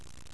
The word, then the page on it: hua: 4